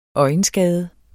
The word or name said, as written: øjenskade